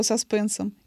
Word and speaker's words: саспенсом: именуемая после него саспенсом, именно так, с этим ударением,